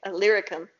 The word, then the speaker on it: Illyricum: That's how you say it. Illyricum.